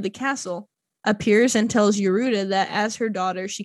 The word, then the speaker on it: Yorda: It's spelled Yorda, but it says the pronunciation is Yoruda.